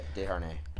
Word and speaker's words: Desharnais: How do you say his last name? Desharnais? – Yeah, Desharnais.